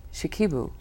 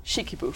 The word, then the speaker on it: Shikibu: Of love poems by Onoko Komachi and Izumi Shikibu. – Shikibu.